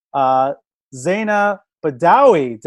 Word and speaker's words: Zaina Badawy: Zaina Badawy.